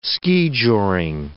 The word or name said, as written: skijoring